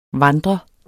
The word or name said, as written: vandre